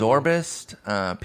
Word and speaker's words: Zobrist: I believe it's Zorbist. Pete Rose gave an official pronunciation on it. I think it's Zorbist. So just wanted to correct you on that.